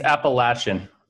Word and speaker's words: Appalachian: Moving on to the New Orleans Bowl, it's going to be Appalachian State giving 6.5. – It's Appalachian. My friends went to Appalachian State, and they whenever you pronounce it wrong, they get upset.